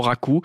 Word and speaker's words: raku: Et puis, l'auditrice céramiste nous dit que pour donner cet effet un peu de brisure à la surface, c'est la cuisson raku. R-A-Q-U. Ça se prononce très clairement raku.